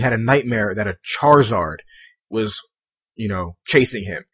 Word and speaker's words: Charizard: And the I is not silent. You do pronounce the I Charizard. You don't say Charizard.